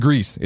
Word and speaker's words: Gris: Some guy writes in and says, uh, Gris, Grease, uh, Gris is pronounced Grease. It's Spanish for gray.